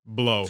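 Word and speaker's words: Blough: And I thought that that kid, Blue, I think is how you pronounce his name. – Blow. – Blow? Is it Blow, or are you just making me say Blow? – No, they were calling him Blow on the during the broadcast.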